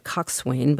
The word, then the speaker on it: coxswain: It's spelled differently from the way it's pronounced. It's spelled C-O-X-S-W-A-I-N. So it looks like coxswain, but it's coxswain.